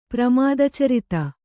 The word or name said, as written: ಪ್ರಮಾದಚರಿತ